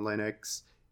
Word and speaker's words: Linux: Uh, correct me if I say this wrong. Is it Linux? It's Linux. – It's Linux. Okay, so, yeah.